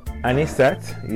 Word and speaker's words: Anisette: Anisette, yes.